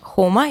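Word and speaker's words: Joma: Вот, э, зачитываю новые комментарии, подписали договор с Джамой, а форму не привозят Хоума. Э, я просто почитала, это испанская фирма, поэтому произносится как, читается как Хома или Хома.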